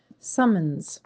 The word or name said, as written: Summons